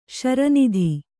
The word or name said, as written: ಶ್ರೀನಾಥ